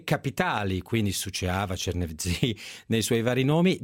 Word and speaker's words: Suceava: Suceava? È corretta la pronuncia? – Suceava, it's correct.